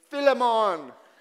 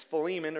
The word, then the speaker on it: Philemon: And it doesn't help that all week my wife has been going around the house and in a Jamaican accent saying, Philemon! Which is not how you say it. The majority concludes it's Philemon or something like that.